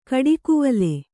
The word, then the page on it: ಗಿಡಗಾವಲು: Kannada